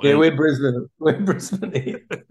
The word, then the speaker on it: Brisbane: Is that, did I say it right? Brisbane? – Brisbane, you say.